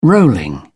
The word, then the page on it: rolling: British English